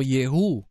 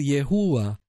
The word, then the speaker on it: Yehú: Voy a anotar de nuevo que yo pronuncio el nombre Yud Hei Vav Hei como Yehú, como la forma simple, la forma más compleja. Si pronunciara la última letra, entonces debería de decir Yehúa. Pero lo voy a pronunciar y lo estoy pronunciando como Yehú sin pronunciar la última parte, porque esa es la forma simple en que aparece en todos los nombres hebreos. Si usted quiere decir Yehúa, dígalo. Y si no, deje que el mismo Ruach HaKodesh lo guíe.